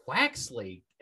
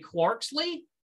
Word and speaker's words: Quaxly: Quaxly. Excellent pronunciation. I was going to say Quarksly, and clearly that's wrong because there's no R.